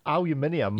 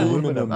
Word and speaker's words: aluminium: It's not different, it's correct. – Ah. – Okay? It's correct. It's spelled differently, I think, is it not? Aluminium. – Yeah. – Aluminium.